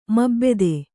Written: ಮೋಬೆದ

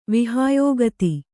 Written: ವಿಹಾಯೋಗತಿ

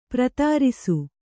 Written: ಪ್ರತಾರಿಸು

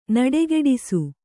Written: ನಡೆಗೆಡಿಸು

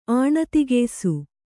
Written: ಆಣತಿಗೆಯ್ಸು

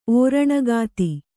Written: ಓರಣಗಾತಿ

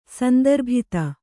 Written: ಸಂದರ್ಭಿತ

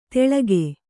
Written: ತೆಳಗೆ

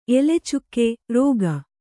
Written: ಎಲೆಚುಕ್ಕೆ(ರೋಗ)

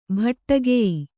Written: ಭಟ್ಟಗೆಯಿ

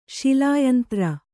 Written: ಶಿಲಾಯಂತ್ರ

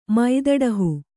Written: ಮೈದಡಹು

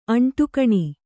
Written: ಅಂಟುಕಣಿ

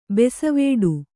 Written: ಬೆಸವಡೆ

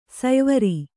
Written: ಸಯ್ವರಿ

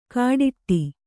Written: ಕಾಡಿಟ್ಟಿ

